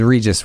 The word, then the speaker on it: WREGIS: All right, it was WREGIS with a silent W.